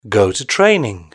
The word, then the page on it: go to training: British English